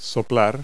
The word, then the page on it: blow: verb